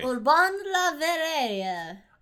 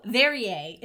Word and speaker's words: Urbain Le Verrier: But it's like, well, how do you pronounce Perrier? Verrier. It's Verrier. Urbain Le Verrier.